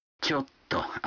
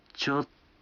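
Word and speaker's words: чотто (ちょっと): Чотто означает дословно «немного», но растягивая окончание и ослабляя ударение, так что получается «чотто».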